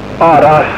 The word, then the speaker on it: Aaraas: Aras. – How do you spell that? – A-A-R-A-A-S. – A-A-R-A-A-S.